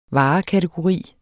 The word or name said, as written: varekategori